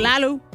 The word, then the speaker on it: Lilou: Lalu. I think that's how you say her name, is L-I-L-O-U. Lalu.